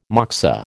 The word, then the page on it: moksa: American English